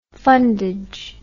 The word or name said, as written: fundage